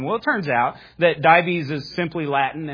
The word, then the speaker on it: Dives: I thought it was Dives at first. It turns out it's not. It's Dives is the correct pronunciation of it.